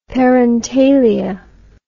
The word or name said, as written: parentalia